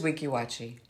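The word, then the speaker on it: Weeki Wachee: probably have heard of Weeki Waki. – Yeah. – Except I would pronounce it Weeki Wachi in my head. – No, it is Weeki Wachi.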